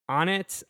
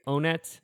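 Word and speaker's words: Onett: Onnit. I think some people say Onnit. I pronounce it Onnit because that makes sense. – It's always been Onnit to me, but it should be Onnit.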